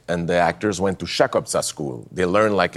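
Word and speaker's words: Chakobsa: There's a language, the Fremen language, Chukotka. Am I saying that correctly? – Absolutely, Chukotka.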